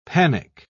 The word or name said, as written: panic